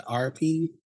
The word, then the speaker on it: Arapeen: After Wakara's death, his brother, now I don't know how to say this all the way, Arapen, is that right? – What is it? – A-R-A-P-E-E-N. Is it Arapen? – Sure.